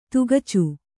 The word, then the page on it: ತಗಚೆ: tagace